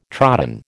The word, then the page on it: trodden: American English